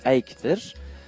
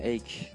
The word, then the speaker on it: Eyck: Tamamen yeni bir şeyi betimlemek için devrimci buluşları hemen fark edilen e, sanatçı olan Can Jan van e, Eyck'tir. Sanıyorum böyle okunuyor Hollandaca'da bu değil mi? Eyck diye okunuyor.